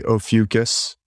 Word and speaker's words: Ophiuchus: And it says that there's two ways to pronounce it. So I always say Ophiuchus. That dictionary has both Ophiuchus and Ophiuchus. So some people say Ophiuchus with a kind of like oligarchs or oligarchs, you know, take your pick.